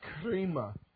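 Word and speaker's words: krima (κρίμα): That word judgment, the word is krima. K-R-I-M-A is pronounced that way, or it's pronounced K-R-E-E-M-A-H, krima.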